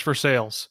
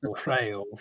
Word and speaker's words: Versailles: You know, it reminds me when I lived in Kentucky, my wife's from the Lexington area, and there's a city over there that if you're educated, you probably think is pronounced Versailles, but no, it's very much Versailles. So Versailles.